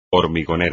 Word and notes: hormigonera: espagnol

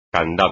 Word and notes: candado: espagnol

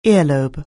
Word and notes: earlobe: anglais

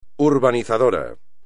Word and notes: urbanizador: espagnol

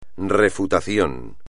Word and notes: refutación: espagnol

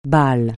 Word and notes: Bâle: francais

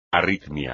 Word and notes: arritmia: espagnol

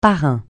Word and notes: parrain: francais

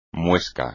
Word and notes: muesca: espagnol

